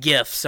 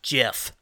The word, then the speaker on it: GIF: People always want to say that, you know, GIF, you know, animated GIFs are pronounced JIF. Like peanut the butter. Fucking peanut butter. – Yeah. – And there's even people saying, well, the original person that made the GIFs actually called them JIFs. Okay, I don't care what the guy said. That's not how it's pronounced. – No, I'm pretty sure it's GIF. – The G doesn't make a J sound.